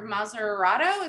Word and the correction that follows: Matarazzo: is that how you say her name? – Matarazzo. – Yes, something like that.